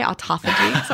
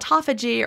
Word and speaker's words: autophagy: And also, autophagy is not – sorry, I said the American way, autophagy. Sorry. I love your Australian pronunciation. That really is how people say it in Australia, anyone who's listening.